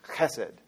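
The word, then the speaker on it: chesed (חסד): And to say it right, we've talked about this word before, but to say it right, you kind of have to get a little loogie going there. You're like, huh. It's guttural. It's chesed.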